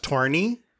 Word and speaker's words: Toni: I believe it's pronounced Torny.